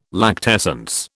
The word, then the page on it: lactescence: British English